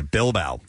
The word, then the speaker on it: Bilbao: I guess that's how you pronounce it. – It is, yeah. – Okay, Bilbao.